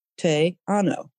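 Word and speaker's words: Te Anau: And Teanao is tricky to pronounce, but if people want to look it up, it's capital T, small e, one word. And then the second word is capital A-N-A-U, Teanao.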